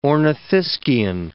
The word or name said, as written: ornithischian